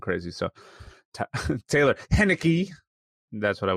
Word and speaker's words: Heinicke: They forced Taylor Heinicke to, I can't say his name.